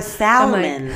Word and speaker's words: salmon: Or salmon. Like, you don't pronounce the L.